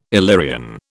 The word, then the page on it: Illyrian: British English